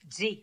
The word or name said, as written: Dzi